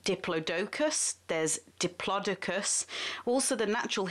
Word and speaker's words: Diplodocus: According to the Oxford English Dictionary, all are acceptable, but the BBC pronunci- pronunciate I can't pronounce the word pronunciation unit has decided that the correct way to say it is diplodocus, and as this is a science broadcast, I'm going to be saying diplodocus the correct way.